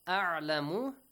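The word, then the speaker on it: أَعْلَمُ: Huwa a'lamu. Make sure you pronounce hamza and ayn correctly. A'lamu. Not a'lamu. Or not a'lamu. And also not a'lamu. A'lamu. A'lamu. Okay? – Yes.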